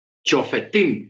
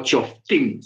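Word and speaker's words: Shoftim: Eh, estamos en la paracha Chofetín o Choftín, se puede pronunciar de las dos eh, maneras. Dios me lo bendiga. – Amén, hermana. – chofetín o choftim.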